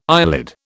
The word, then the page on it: eyelid: British English